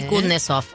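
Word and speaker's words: Kusnetzoff: Vuelve, a ver si me sale, Kutnesov. – Correcto, sí, Telefe. – Andy Kutnesov, así se puede pronunciar.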